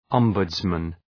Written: ombudsman

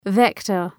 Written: vector

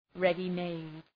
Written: ready-made

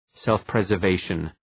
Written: self-preservation

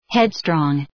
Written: headstrong

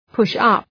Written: push up

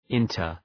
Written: inter

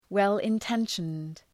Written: well-intentioned

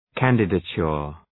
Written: candidature

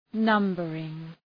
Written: numbering

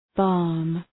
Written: balm

